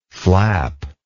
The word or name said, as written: flapless